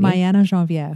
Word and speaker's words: Mayana Geneviére: Mayana Geneviére. – Yeah, Mayana. Why can't I say it? Mayana Geneviére.